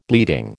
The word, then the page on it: bleeding: American English